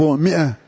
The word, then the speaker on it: مئة: مثلا لفظ مئة مكتوب في المصحف ماء بالألف، ما تنطق، لا تنطق هذه.